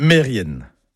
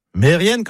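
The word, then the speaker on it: Merien: Eh ben non, tout ça, c'est faux. C'est Mérienne, Kermérienne.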